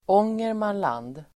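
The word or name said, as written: Ångermanland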